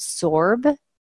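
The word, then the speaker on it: FSORB: Well, we found this one manufacturer called Sorb. It's spelled capital F, capital S-O-R-B.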